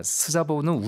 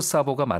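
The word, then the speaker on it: Szabó: 그 사보, 아 스자보는 우리식 발음이고요. 아, 예, 예. 그 가보, 사보, 가보로 이렇게 얘기한다고 합니다. 반대로 얘기하기도 하고요. 아, 이제 가보로 사보가 맞는 거죠. – 네. 네. 그러니까 Z가 무음이군요. 여기서.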